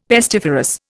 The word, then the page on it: pestiferous: Indian